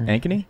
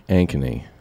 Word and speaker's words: Ankeny: Davenport, uh, somewhere else, and uh, Anchor Anchorney Anchor Ankeny? – Ankeny.